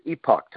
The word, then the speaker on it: Epoch: So, and there's some great publications, the Epoch, I think it's pronounced Epoch Times. Some people say Epic, but it's spelled E-P-O-C-H Times.